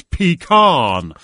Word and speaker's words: pecan: I said on a seasonal show many years ago, I said, I asked Martha Stewart about her recipe for pecan pie, and she said, it's pecan.